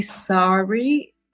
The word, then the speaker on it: Sari: S-A-R-I. How do I say your name? – The English like to call me Sari. The Americans like to call me Sari. Make of it what you will.